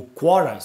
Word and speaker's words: Quora: o Quora, se não me engano é assim que se pronuncia, Q-U-O-R-A,